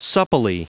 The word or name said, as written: supplely